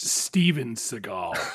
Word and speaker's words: Seagal: Or Segal? Segal? S-E-G-A-L? I've seen this person in many things, but it's pronounced Steven Segal. – You're right. I'm mispronouncing the first name. Man, Elliot Gould and Steven Seagal.